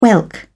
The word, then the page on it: whelk: British English